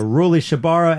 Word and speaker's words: Rully Shabara: Finally, an album called Gagu by Lucas Abela, Ruli Shabara, and Ramberto Agozalie. – Three... – Did I say that right? You gave me a nod of approval.